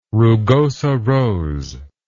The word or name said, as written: rugosa rose